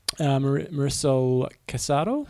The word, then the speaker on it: Marisol Casado: After a week of meetings at Ironman headquarters in Tampa, Florida, senior leaders from both organisations, including ITU president and IOC member, how do you say that one? – Marisol Casado and Ironman President Andrew Messick agreed to a historic growth framework that is the accumulation of several years of work together.